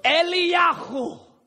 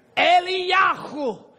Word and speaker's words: Eliyahu: You want to say his name in Hebrew with me? Eliyahu. Eliyahu. Say it, Eliyahu. You have to get a little phlegm. If you don't taste anything, you're not saying it right.